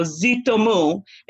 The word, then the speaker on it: Zoutomou: I mean, as you know, I'm originally from West Africa. So Zoutomou is the easiest way to say it.